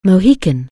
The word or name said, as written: Mohican